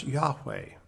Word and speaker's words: Yahweh: it actually uses this YHWH. And it's pronounced Yahweh.